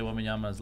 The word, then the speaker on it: Wembanyama: How do you say Wembanyama's last Wembanyama. – Name? – Wembanyama. – What's his first name? – Victor Wembanyama. – Oh, man. Man, I hope. I really hope his name is Wembanyama.